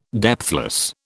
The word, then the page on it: depthless: British English